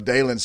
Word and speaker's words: Dylan: You know, Dalen's spelled D-Y-L-A-N, and it's pronounced Dalen. And I kept telling all those guys up there, I'm telling you guys, it's Dalen. It's not Dylan.